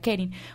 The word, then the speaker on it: Karin: Karin for Colombia, se escribe Karin, pero se pronuncia Kerin, para que todas las personas entonces estén muy atentas y puedan realizar sus donaciones.